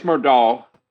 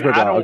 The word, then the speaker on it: Murdaugh: Murdaugh. – Oh, it is Murdaugh.